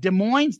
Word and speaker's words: Des Moines: Love both listening to Soundboard at Des Moines, the S is silent in 77, and your coverage of it.